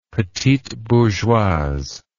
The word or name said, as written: petite bourgeoise